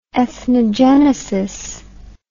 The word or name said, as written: ethnogenetic